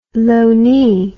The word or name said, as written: loanee